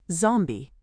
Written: zombie

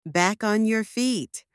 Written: back on your feet

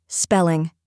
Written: spelling

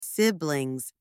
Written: siblings

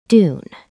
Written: dune

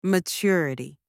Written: maturity

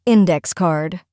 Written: index card

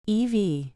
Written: EV

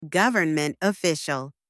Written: government official

